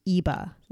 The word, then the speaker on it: EBE: So it's spelled E-B-E, but it is pronounced EBA, like it has an A at the end.